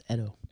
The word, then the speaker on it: Edo: It's actually pronounced Edo.